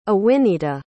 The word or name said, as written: awinita